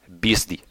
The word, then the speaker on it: Beastie: Uh, their mascot is called Beastie. It's a distorted pronunciation of BSD. Beastie.